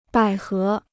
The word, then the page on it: 百合: bǎi hé